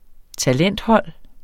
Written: talenthold

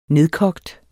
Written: nedkogt

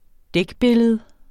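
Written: dækbillede

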